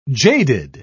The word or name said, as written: jaded